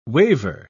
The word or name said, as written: waver